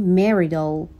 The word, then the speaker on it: Marital: For Marital and Family Studies، Marital یا Marital، توی تلفظ آمریکایی این تی صدای شبیه به د هستش.